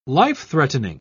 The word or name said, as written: life-threatening